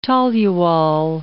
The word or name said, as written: toluol